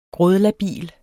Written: grådlabil

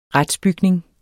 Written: RETSBYGNING

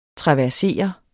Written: traversere

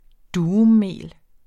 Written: durummel

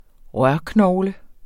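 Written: rørknogle

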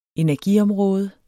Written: energiområde

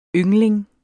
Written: yngling

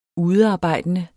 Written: udearbejdende

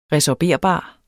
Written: resorberbar

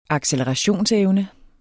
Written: accelerationsevne

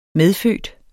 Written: medfødt

